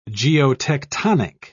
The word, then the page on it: geotectonic: British English